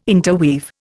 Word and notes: interweave: Indian

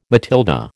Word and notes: Matilda: American English

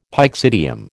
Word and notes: pyxidium: American English

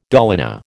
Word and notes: dolina: American English

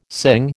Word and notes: sing: American English

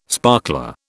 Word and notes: sparkler: British English

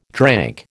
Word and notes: drank: American English

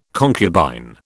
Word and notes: concubine: British English

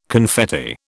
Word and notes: confetti: British English

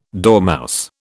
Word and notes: dormouse: British English

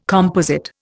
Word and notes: composite: Indian

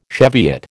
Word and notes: Cheviot: American English

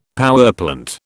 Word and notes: powerplant: British English

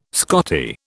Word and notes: Scottie: British English

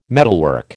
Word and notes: metalwork: American English

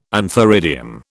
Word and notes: antheridium: British English

